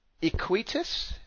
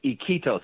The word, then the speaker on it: Iquitos: But where we will go is your new book, which is Iquitos. Am I saying it right? – No, it's Iquitos.